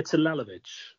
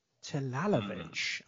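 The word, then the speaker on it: Talalović: Talalovic. Ah, when I was writing that down, it was like, no, I didn't pronounce it like that. But now I'm looking at it, yeah, you made that sound.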